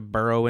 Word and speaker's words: Burrow: You could say that he is like a little hedgehog and he likes to burrow into the ground. – Mm-hmm. – That's a silly one. Second, Burrow rhymes with furrow.